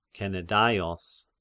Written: Kenedaios

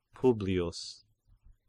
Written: Publius

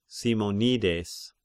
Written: Simonides

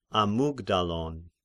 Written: Amygdalon

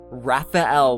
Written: Raphael

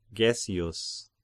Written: Gessius

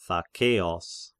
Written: Thakeos

